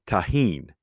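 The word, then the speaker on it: Tajín: I believe, called tahin, and it's T-A-J-I-N.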